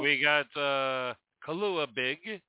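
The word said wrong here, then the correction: kalua pig: a Kahlua Pig, let's say it right.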